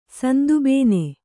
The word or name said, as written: ಸಂದುಬೇನೆ